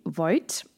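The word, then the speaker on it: Voight: Voigt, will ich immer sagen, Voigt geschrieben, aber Voigt ausgesprochen. Ihr Vater ist durchaus bekannt.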